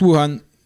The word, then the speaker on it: Wuhan: Alors on prononce Wuhan, c'est ça ou Wuhan euh? – On prononce Wuhan.